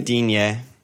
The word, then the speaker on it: Digne: How do you how do you say his name? Yeah, Dean, Digne. – Digne is fucking French guy.